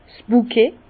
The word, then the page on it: 's Bukett: Bas-Rhin; Herrlisheim